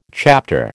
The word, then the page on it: chapter: American English